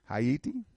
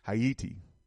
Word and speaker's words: Haiti: Benito Sylvain was in fact the representative of two of the only three independent African countries at this period Haiti, Haiti, Haiti, that's how you should pronounce that, brothers and sisters, Haiti, and Ethiopia at this conference.